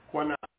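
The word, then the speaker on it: qanna: The word is Kanah, K-A-N-N-A-W. Kana. It's pronounced Kana, but it's Q-A-N-N-A. But it's pronounced Kana with a K.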